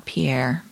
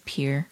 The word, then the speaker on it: Pierre: It's not Pierre, by the way. – It's not Pierre? – No, it's Pierre.